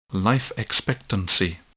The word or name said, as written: life expectancy